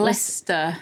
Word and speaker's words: Leicester: I can't remember exactly which ones it was, but it Leicester.